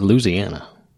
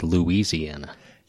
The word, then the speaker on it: Louisiana: They say it Louisiana. – Yeah, they do. – Everybody else that's not from there says Louisiana.